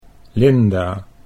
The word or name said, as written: Linda